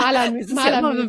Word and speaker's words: Malamü: Ja, äh, ja, ich heiße äh, ähm, auf Instagram und mein Label heißt Malamü. Auf Instagram ist es Malamur, deshalb ist das manchmal mit der Aussprache auch ein bisschen schwierig.